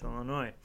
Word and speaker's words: Illinois: Because they don't pronounce the S in Illinois. So right. – It's Illinois. Yeah.